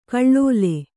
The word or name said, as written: ಗಳಲೆ